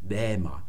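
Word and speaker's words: bäma (βῆμα): Man sagt dann also to bäma. Das ist die altgriechische Aussprache.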